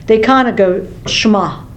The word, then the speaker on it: Shema: Now in Hebrew, the E is pronounced like a long A, so it's Shema.